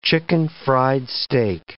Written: chicken-fried steak

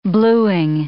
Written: blueing

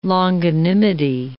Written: longanimity

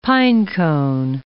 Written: pinecone

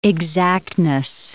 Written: exactness

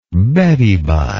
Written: beddy bye

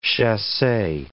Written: chasse

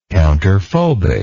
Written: counterphobic

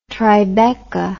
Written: Tribeca

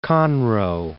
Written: Conroe

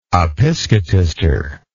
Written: episcotister

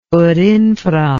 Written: ut infra

